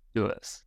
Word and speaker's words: doos: And the word is doos. So you spell it D-O-O-S, doos.